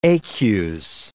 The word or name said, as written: ecus